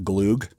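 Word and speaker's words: glögg: It's pronounced Gloog, but it's spelled Glog. – Yeah, it's spelled Glog. – And you'll hear a lot of people call it Glog, yeah, Midwesterners.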